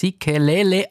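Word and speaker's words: Sikelel': Nkosi Sikele Afrika. Spricht man das richtig so aus? Sikelele Afrika, glaube ich, ist ganz genau.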